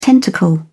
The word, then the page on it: tentacle: British English